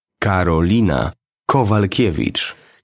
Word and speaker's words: Karolina Kowalkiewicz: The newest UFC Polish female fighter is Karolina Kowalkiewicz.